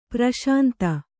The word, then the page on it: ಪ್ರಶಾಂತ: praś`nta; kannada